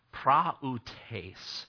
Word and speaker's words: prautes: The Greek word used here in this section of Scripture for gentleness is P-R-A-U-T-E-S. And it's pronounced praoutes.